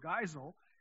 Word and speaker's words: Geisel: I wasn't sure whether to say Geisel or Geisel, and there's an app. There's a place on the web where it'll tell you how to pronounce somebody's name. And so it's Geisel, Dr. Seuss.